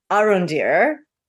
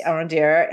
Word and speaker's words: Arondir: His name is Arondir. – Yes. – Arondir. And he is portrayed by a Puerto Rican actor named Ismael Cruz Cordova. And so apologies if I didn't get that right. But Arondir is probably the one lead.